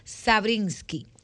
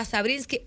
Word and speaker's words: Sabrinsky: DRA Sabrinsky tal y como se escribe mi nombre, S-A-B larga R-I-N-S-K de kilo Y. Derea Sabrinsky en todas nuestras